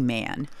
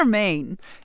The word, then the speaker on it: Mane: Gucci Mane. – I still don't know. Is that man or Mane? – It's Mane, but, you know, I'm cool with man, too. It's just some people pronounce it different.